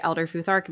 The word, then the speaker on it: Elder Futhark: So you mentioned that you work with the Elder Futhark. Am I saying that right? Okay, correct. Perfect.